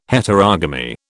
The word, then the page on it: Heterogamy: British English